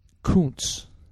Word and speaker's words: Kuntz: Although I thought I heard Rusty's name as Kuntz. – That is how you pronounce it.